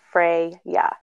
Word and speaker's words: Freya: It is pronounced Freya, and it has a couple different spellings. So just know it has one way to pronounce, even though it's spelled differently.